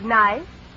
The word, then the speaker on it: knife: And we are going to study words which contain letters that we don't pronounce. Now, will you volunteer to spell the word knife? – Knife. K-N-I-F.